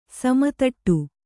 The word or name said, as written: ಸಮತಟ್ಟು